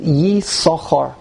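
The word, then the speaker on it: Yissachar: Yisachar is spelled in the Torah with two sins. So, and in fact, by Ashkenazim, we always pronounce it Yisachar. We ignore the second sin.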